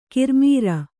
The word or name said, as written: ಕಿರ್ಮೀರ